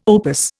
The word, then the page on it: opus: Indian